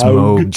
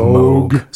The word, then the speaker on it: Moog: And Moog just had an anniversary not too long ago Moog. – That we've talked about before. – It's Moog, Joe. It's Moog.